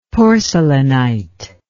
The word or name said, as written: porcelainite